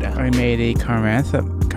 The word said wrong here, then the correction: Chrysanthemum: Is that how you say it, Ali? – Chrysanthemum? – Chrysanthemum. – Like the flower? – Yes, I can't say that word.